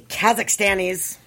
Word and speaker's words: Kazakhstanis: Well, those crazy Kazakhstanis. I have no idea if I said that right. – You did. – You did. – Okay. Nice. – According to what I've been hearing. – Yes. Kazakhstan.